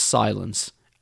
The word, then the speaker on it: Cylance: They're spelt C-Y-L-A-N-C-E, pronounced Silence.